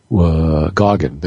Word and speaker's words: Goggin: Anthony Goggin, I'm probably saying it wrong, I'll look it up before the show. Goggin, that's your pronunciation, I had it written down.